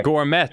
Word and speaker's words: Gourmet: Oh, the legendary, hey, the Gourmet, rhymes with Chalmet.